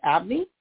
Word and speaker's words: Abney: And if you have any topics that you would like for me to discuss, you can Google my name, Jeanette Abney, which is spelled J-E-A-N-E-T-T-E. Last name is A-B-N-E-Y.